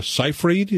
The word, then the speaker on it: Seyfried: Amanda Seyfried, is that how you pronounce her name? – Yeah.